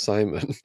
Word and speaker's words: Simon: You remember the time that I decided that my name would be spelt without the final N but still pronounced Simon?